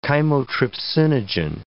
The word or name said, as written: chymotrypsinogen